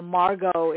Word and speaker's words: Margot: And I want people to know that while Margot is how you say her name, Margot does spell her name with a T.